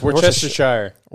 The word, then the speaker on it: Worcestershire: It's Worcestershire. It's not Worcestershire. – Worcestershire. – So what's this here sauce? – Worcestershire sauce. – Because the way it's spelled. – There, I know the word shire's in it. – Exactly. So you can't just say sure.